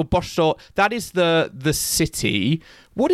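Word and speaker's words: Båstad: Well, you already have, Joel, with that, because it is Bostor. It's not Bastad, it's not Gastad, it's none of those, Joel.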